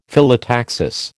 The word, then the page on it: Phyllotaxis: American English